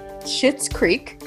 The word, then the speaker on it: Schitt's Creek: Schitt's Creek. – And it's spelled, just in case my mom is listening, it's spelled S-C-H-I-T-T-S.